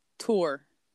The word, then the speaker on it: tour: Well, do you pronounce T-O-U-R? How do you say that? I'm going on a tour. – Yeah, I say tour. Like, I always put the ooh in there, like I'm French.